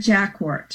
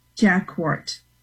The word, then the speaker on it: Jacquart: Joanne Jacquart. – Spell that. – J-A-Q-U-A-R-T. – So hold on, say the whole thing again slowly. – Joanne Jacquart. Yeah, as an author. – Spell both words. – J-O-A-N-N-E J-A-C Q-U-A-R-T, Joanne Jacquart.